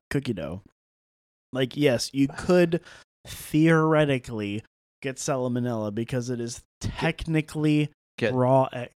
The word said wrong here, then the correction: salmonella: Did I add a syllable? – You did. – It's salmonella.